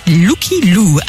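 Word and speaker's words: Lykke Li: Il est signé Licky Lick, il faut paraît-il prononcer Lucky Lou, artiste suédoise qui a explosé à l'international grâce à ce titre.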